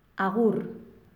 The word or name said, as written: agur